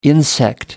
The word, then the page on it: insect: American English